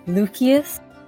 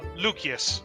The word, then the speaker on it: Lucius: So, you know, I thought I'd return the favor, and so I push another beer towards Lucius. – Lucius. There's no soft C in Latin.